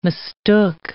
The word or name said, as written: mistook